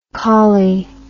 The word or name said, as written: Cawley